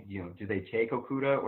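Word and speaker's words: Okudah: I have Detroit taking Okuda. Sorry, I don't know how to say it. – Okuda, yeah.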